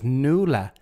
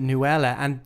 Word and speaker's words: Nuala: But that's, as anyone in Ireland would know, is Nuala. – Yeah. – So she says that she's Nuala Windsor, but she pronounces it Nuella.